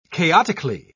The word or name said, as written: chaotically